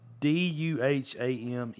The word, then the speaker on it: Duhamel: Dumel? – Or something. – Fergie's hot husband. – I got it right here. It's Duhamel. Duhamel.